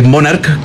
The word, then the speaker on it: Monarch: Ya la CH en Italia se pronuncia como una K. Entonces es Monarch, que viene de Monarca o el rey de los calcetines.